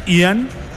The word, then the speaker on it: Ian: Me gusta tu pronunciación británica, es Ian. – ¿Ian? – Ian. – Ian Poveda. – Ian, like a water.